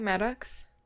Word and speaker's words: Maddux: Maddux? Is that how you'd say it? Maddux? M-A-D-D-U-X? – Sure, yeah.